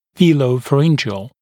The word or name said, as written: velopharyngeal